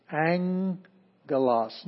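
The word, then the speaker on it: angelos (ἄγγελος): the first gamma has a ng sound, ng, ng. And then the second gamma actually does still have the g sound, which is not like j, it's not like jif, it's g, as in girl. It's a hard G. So, in this case, this is angelos.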